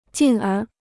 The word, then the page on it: 进而: jìn ér